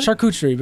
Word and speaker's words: charcuterie: Charcuterie, because I can't remember how to actually pronounce charcuterie. – It. Charcuterie. – Cooter. – Cooter. You just gotta remember.